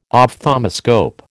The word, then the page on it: ophthalmoscope: American English